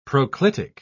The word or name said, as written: proclitic